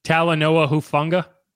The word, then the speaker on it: Talanoa Hufanga: Talanoa Hufanga.